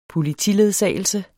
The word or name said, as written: politiledsagelse